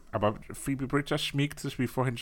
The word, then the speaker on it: Phoebe: Phoebe spricht man das aus. – Phoebe. – Ja, wie Phoebe von Friends.